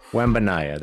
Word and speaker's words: Wembanyama: And they have a chance at Wembanyama. How am I saying that right? – Wembanyama. – There you go.